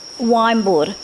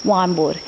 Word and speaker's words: Waymbuurr: Wimbuud, it's spelled W-A-Y-M-B-U-U-R-R, Wimbuud.